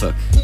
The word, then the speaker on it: Norfolk: It's Norfolk. – Oh, dear. – Suffolk and Norfolk.